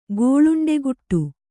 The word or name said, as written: ಗೋಳುಂಡೆಗುಟ್ಟು